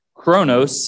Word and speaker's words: chronos: Chronos is spelled C-H-R-O-N-O-S. C-H-R-O-N-O-S. Chronos, kind of like chronological. And the second word is kairos. It's spelled with a K at the beginning. K-A-I-R-O-S.